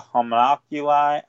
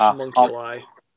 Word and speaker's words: homunculi: And the reasons for that mainly have to do with the homunculi. I can't even pronounce it. – I think it's homunculi.